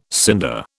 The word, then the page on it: cinder: British English